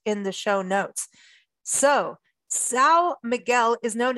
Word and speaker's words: São Miguel: But today she's going to be talking about São Miguel Island. Did I get that part right? – Yes.